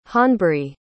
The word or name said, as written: honbrie